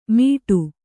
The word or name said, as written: ಮೀಟು